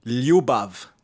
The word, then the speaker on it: Ljubav: So the J in Croatian, just to get technical, is a Y. – Yeah, it's a Y sound. It's a Y. – Oh, yeah. Ljubav.